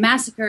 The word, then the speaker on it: Massaker: And Massacre is spelled with two S's, MASSAKER, correct? – That's right. Just like Texas Chainsaw, but spelled a little different.